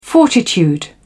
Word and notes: fortitude: British English

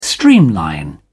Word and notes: streamline: British English

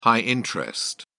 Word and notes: high-interest: British English

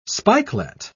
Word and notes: spikelet: British English

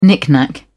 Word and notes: knick knack: British English

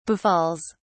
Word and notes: befalls: British English

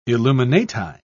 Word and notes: illuminati: British English

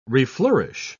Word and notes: reflourish: British English

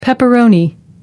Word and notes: pepperoni: American English

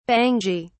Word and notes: bangy: British English

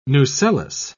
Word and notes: nucellus: British English

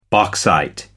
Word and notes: bauxite: American English

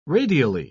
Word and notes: radially: British English

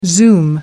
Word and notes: zoom: American English

